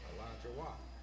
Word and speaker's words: Alachua: Now, did I pronounce that right? Alachua. Or should I say Alachua? – Alachua. – All right. Well, I'll start pronouncing it right.